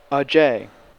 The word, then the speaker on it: Ajay: Then again, Ajay can be pronounced with Anglo-American phonemes. – A blue jay. Ajay.